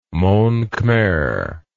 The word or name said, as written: monkhmer